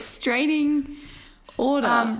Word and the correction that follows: restraining order: Restraining order.